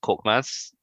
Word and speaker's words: Korkmaz: I'll start off with Galatasaray legend, Bulent Korkmaz.